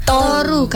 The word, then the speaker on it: Toru: Toru, Kak. Toru. Karena mengambil dari bahasa Jawa, Turu. – Turu. – Tidur artinya.